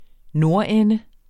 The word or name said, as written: nordende